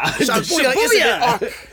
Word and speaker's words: Shibuya: Shibuya.